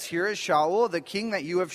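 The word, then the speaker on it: Shaul (שאול): Saul's name in Hebrew is pronounced Shaul. Shaul comes from the Hebrew word sha'al, which means to ask.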